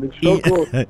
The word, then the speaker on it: Inhloko: Inhloko. Did I say it right? – Please pour me some Inhloko. So you give me a clue for that one. – Inhloko.